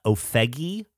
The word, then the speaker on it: Ofege: There's a song called It's Not Easy by Ofegi, I believe is how you say it. O-F-E-G-E, which is a really fantastic song.